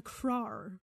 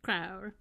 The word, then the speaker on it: Kraur: This is called a Krar, I think is how it's pronounced. K-R-A-U-R. Krar.